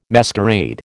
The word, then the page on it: masquerade: American English